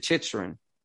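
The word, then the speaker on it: Chytil: With Chikrin. – I just listened to like how they pronounce it. They pronounce it Chitrin.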